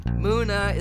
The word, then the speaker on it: Muna: Anyway, so Muna is how you say it.